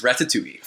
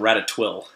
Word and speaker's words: Ratatouille: The Incredibles versus Ratatouille. – Fun fact, my roommate can't pronounce Ratatouille. She calls it Ratatwill.